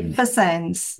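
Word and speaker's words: FSANZ: How do you say that? – Food Standards, Australia, New Zealand. – FS. – I say FSANS. – FSANS, okay.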